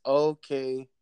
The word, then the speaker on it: Okoye: It's Okoye.